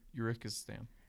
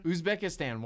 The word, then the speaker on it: Uzbekistan: Well, let's face it, they're all from Uzbekistan. – But I said that wrong. – Uzbekistan.